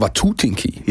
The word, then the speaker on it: Watutinki: Ich habe aber mittlerweile gelernt, es heißt Watutinki. Ja. ja. Die Betonung liegt auf dem, auf dem ersten Block.